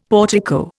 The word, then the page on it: portico: Indian